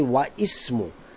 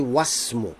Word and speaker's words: wasmu: Now you see, I don't say wa ismu. I say wasmu.